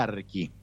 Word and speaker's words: Archi: Se llama Archi, este pueblo, se escribe Archi, CH, Archi, se pronuncia Archi.